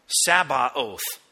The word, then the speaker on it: Sabaoth: This one, important to get, Yahweh Sabaoth, often spelled without the T, but it starts with a T, actually, a T sound.